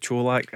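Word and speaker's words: Čolak: Cholak, I'll, I'll go with Cholak. I heard Craig Moore saying that's the way you've got to pronounce it.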